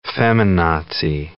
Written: feminazi

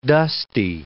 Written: dusty

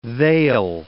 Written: they'll

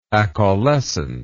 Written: acaulescence

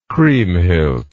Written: kriemhild

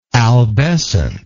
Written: albescent

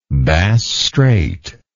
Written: Bass Strait

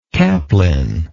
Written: caplin

